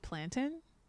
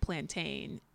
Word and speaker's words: plantain: So this is an ongoing sort of joke in like Caribbean culture where some people refer to it and they pronounce it plantain. And then in Jamaica in particular, it's plantain. And there's so many cultural references for this.